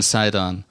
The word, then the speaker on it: Sidon: And leaving there, Jesus departed to the areas of Tyre and Sidon. And Sidon's how you pronounce it.